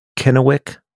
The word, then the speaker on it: Kennewick: And this is in Kennewick, Washington. Did I say that right? – Yes, my older brother lived there for a time.